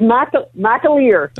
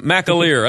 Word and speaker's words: McAleer: How do you pronounce his name again? – So it's McAleer. – Okay. Phelan McAleer.